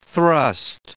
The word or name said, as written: thrust